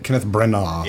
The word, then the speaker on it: Kenneth Branagh: Kenneth Branagh.